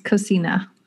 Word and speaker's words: cocina: They're having another builder hour on Friday to showcase the Cocina app, I think you're not from Texas and this is pronounced Cocina. – See, I'm terrible. Cocina app.